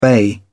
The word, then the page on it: bay: British English